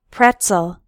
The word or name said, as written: pretzel